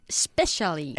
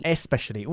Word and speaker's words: especially: Especially. – Especially. Una palabra que sí empieza con la E. No es como Spain. – Ah. – Especially.